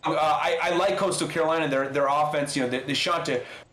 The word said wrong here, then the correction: Chanticleer: Chanticleer. She got to pronounce it very distinguished, the Chanticleer, I do declare.